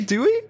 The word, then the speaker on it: doei: Yeah, in Dutch we say doei. – How do you say doei? Doei. – Doei. Doei. – Bye-bye. – It's like bye.